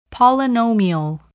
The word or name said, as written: polynomial